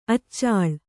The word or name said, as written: ಅಚಲೆ